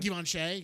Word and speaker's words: Givenchy: Givenchy, yes.